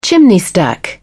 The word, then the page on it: chimney stack: British English